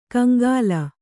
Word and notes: ಕಂಗಾಲ: kaŋgāla

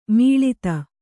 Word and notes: ಮುಳ್ಳೊತ್ತು: Kannada

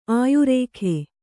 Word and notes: ಆಯುರೇಖೆ: āyurēkhe

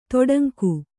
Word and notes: ತೊಡಂಗು: toḍaŋgu

